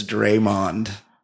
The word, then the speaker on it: Draymond: It's really funny that I got Draymond Green's name wrong, and I kept saying it Draymond. – It's taken you 2 days to figure out that it's Draymond. Not what were you saying? – His name is Draymond.